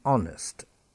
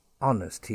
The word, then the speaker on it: honest: So the letter H is always silent in words like heir, the heir to the throne, H-E-I-R, silent H. Let's be honest, honest, he is an honest man, H-O-N-E-S-T.